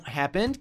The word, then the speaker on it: happened: No entanto, muitas vezes americanos vão juntar esse happened como se o H não existisse.